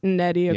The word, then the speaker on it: Nnedi: Nnedi Okorafor.